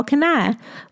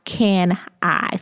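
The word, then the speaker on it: Kani: When I first heard of the brand, they said Carl Kanai. But it's pronounced Can I. So Carl Can I.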